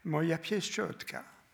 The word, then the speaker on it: Moja pieszczotka: C'est, euh, en, polonais, ça se prononce Moja pieszczotka, ma, ma mignonnette, ma, chérie.